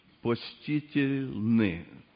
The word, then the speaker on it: почтительны: По-русски перевод «почтительны». Правильное ударение? «Почтительны».